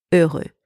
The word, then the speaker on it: heureux: So let's start with the masculine form of the adjective heureux. Heureux. First, very important, the H is silent in French, so don't pronounce it. Next, the letters E and U in that word are pronounced E. E.